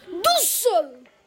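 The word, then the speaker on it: Brunetta d'Usseaux: Eugenio Brunetta Bruxelles? Cavoletti di Bruxelles? no! Dussel!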